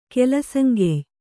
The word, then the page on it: ಕೆಲಸಂಗೆಯ್: kelasaŋgey; kannada